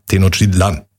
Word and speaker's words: Tenochtitlan: Der Apostroph ist auf, oder der, die Betonung liegt auf dem letzten A. Tenochtitlan.